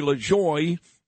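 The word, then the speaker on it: LaJoie: And then we'll do Bo Dietl, Chadwick Moore, and NASCAR driver Corey LaJoy in the final 9 o'clock hour. I did say that right, yes.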